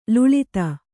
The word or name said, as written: ಲುಳಿತ